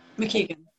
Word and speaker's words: McKeegan: Tracy McKeegan. McKeegan. – McKeegan. Yeah, you said it right.